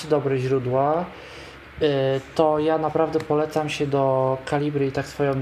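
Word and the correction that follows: Calibre: drogą zapisać, to Kaliber właściwie, bo to tak się powinno wymawiać, bo to brytyjska pisownia.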